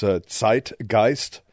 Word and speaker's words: Zeitgeist: If I pronounce it properly in German, it's Zeitgeist. English pronunciation, maybe Zeitgeist or something.